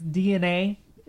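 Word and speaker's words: DeNA: I think it's DNA is what how it's pronounced.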